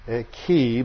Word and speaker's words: chi: The word chiasm comes from the Greek letter chi, or as we're taught to pronounce it, chi, but it was older ways of pronouncing it was with a long I, and that's like a letter X.